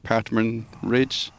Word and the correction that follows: Ptarmigan Ridge: and 12-1 Bar. – Ptarmigan Ridge.